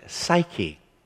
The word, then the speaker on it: psuche: The word here is psuche. That's the Greek word. We would pronounce it psyche.